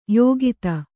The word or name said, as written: ಯುಕುತಿ